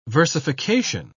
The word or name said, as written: versification